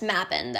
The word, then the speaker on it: Mabon: I say Mabon. I've heard it pronounced Mabon though.